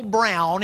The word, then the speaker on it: Broun: By the way, you can go to YouTube and just type in Paul Brown. It's spelled B-R-O-U-N, not B-R-O-W-N, B-R-O-U-N.